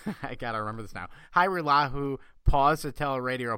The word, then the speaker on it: Hajrullahu: The J is silent. Uh, it's Hyrulahu.